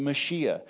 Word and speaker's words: Mashiach: He's the Messiah, the Mashiach, as the Jews would pronounce it in Hebrew.